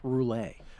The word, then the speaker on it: Rhule: That he had Matt Rhule say to his face when he covered Matt Rhule wherever he was, that you pronounce it Matt Rhule.